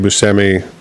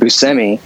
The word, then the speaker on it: Buscemi: So I'm pretty sure he was on the Late Show recently, and Colbert was calling him Buscemi. So I usually defer to like their pronunciation because they probably like figure out how to get it right.